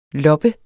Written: LOPPERNE

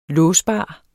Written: låsbar

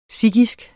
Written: sikhisk

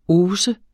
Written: hausse

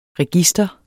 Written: register

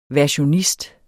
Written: versionist

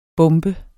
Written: bombe

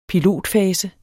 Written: pilotfase

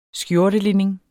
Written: skjortelinning

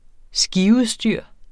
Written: skiudstyr

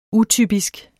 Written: utypisk

